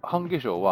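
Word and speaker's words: 半夏生: 半化粧は、半に夏が生きると書いて半化粧って読むんですけど、はい、ということでですね、いろんなこう太陽暦、つまり、太陽の周りを地球が回っていくので何度になったらどの季節っていうタイプの太陽暦として決まっているのが二十四節気ですと。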